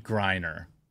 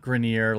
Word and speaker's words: Griner: I think it is Brittany Griner. It is not Grenier, like I kept giving you.